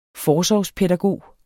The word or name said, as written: forsorgspædagog